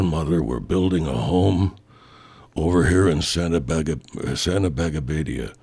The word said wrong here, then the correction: Santa Barbara: Santa Barbara.